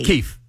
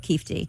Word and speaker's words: Keffe: Now, was Keefe D a rapper too? – Is it Keefe D or Keffie D? – Keefe. – K-E-F-F-E.